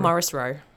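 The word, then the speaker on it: Morrisroe: We're in the Morrissey building. Did I pronounce that right? – Morris Row. – Morris Row building.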